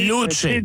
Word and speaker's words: Luce: Mais il faut le prononcer à l'italienne, hein, puis là, tu vas me dire si je suis correct, voilà. Là. Si je suis correct. – Le C, Luce. Devant un E.